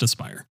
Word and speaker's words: Aspyr: Um, it looks like Aspyr, Aspyr. Uh, it says pronounced Aspire.